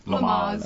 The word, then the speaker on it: Lamaze: Lamaze.